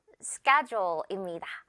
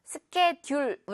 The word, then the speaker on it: schedule: 바로 schedule입니다. 스케 다음에 듀얼처럼 생겼는데 그렇지 않죠. 스케듀얼, 우리 이렇게 발음하지 않죠.